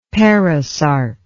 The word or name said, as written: perisarc